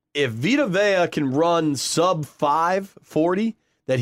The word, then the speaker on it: Vita Vea: I think Vita Vea is the official pronunciation.